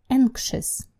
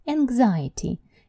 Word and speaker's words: anxious: Word number three – anxious. Anxious – тревожный. Будьте очень аккуратны с этим словом, его очень часто неправильно произносят, потому что, например, существительная тревога будет по-английски anxiety. Anxiety, видите, меняется ш на гз.